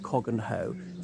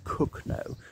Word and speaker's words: Cogenhoe: It looks like Cogganhoe, Cogenhoe, but apparently it's pronounced Cooknoe. But he did not like it over much.